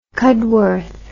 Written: cudworth

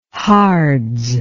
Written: hards

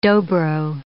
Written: Dobro

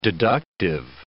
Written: deductive

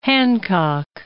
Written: Hancock

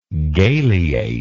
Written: galeate

